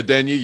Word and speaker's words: Adeniyi: Is that how you pronounce it, Adonijé? – Oh, Ole Adeniyi, yeah.